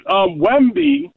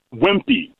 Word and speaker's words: Wemby: All right, how about your Wemby maybe just doesn't have any heart? I'm sorry, not Wemby. I said his name wrong. Wemby.